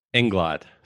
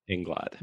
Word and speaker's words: Inglot: Inglot. So it's Matt Inglot.